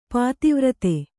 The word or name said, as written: ಪತಿವ್ರತೆ